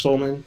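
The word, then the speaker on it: Suleman: He's won an NAACP award for his writing, and his final novel, The Son of Mr. Solman, I believe it's pronounced, it's S-U-L-E-M-A-N, I believe it's pronounced Solman.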